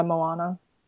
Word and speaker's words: Moana: No, listen, I'm just waiting for Moana.